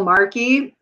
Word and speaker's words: Marki: If people want to check out my videos, they can find my YouTube channel with my name, Michelle Marki. And Marki is spelled M-A-R-K-I and investing.